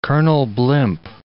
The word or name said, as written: Colonel Blimp